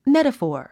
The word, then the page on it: metaphor: métəfɔ̀ː r; メタフォア